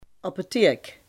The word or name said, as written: apotheek